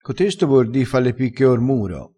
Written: fa le picche cor muro

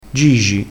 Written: gigi

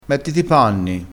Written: panni

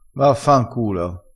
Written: vaffanculo

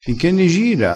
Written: gira

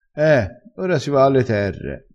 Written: terre

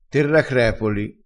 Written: terracrepoli

